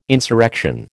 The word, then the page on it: insurrection: American English